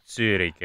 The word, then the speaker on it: Zürich: ci spiega che Zurbriggen e Zurbriggen, Zürich e Zürich, quindi si pronuncia diversamente il primo con la U italiana, perché il secondo c'ha la dieresi, okay.